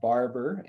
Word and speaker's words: Barbour: So yeah, my name's Cody Barber, and my last name is spelled B-A-R-B-O-U-R, which is why Brett made the joke about the French spelling and everything like that.